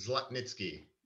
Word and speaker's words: Zlotnitsky: Zla- Zlotnitsky.